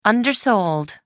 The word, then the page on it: undersold: American English